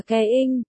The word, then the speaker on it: Khả Doanh: Khả Doanh đọc là Ke Ing, chữ Ing đọc sau âm Ke phát âm rất nhẹ, trọng âm hầu như rơi vào Ke, nên đọc gần giống coi.